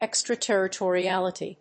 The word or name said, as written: extraterritoriality